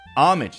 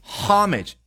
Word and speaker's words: homage: Homage 相当于 tribute 或者 respect 啊，一个人对另外一个人的那一份敬意。那这个词呢有两个读音，一个是 homage，一个是我们刚刚念的 homage，因为它来自于法语，法语当中呢是没有这个声音的啊。据说法国人是没有办法像我们中国人一样哈哈哈笑，他们只能够。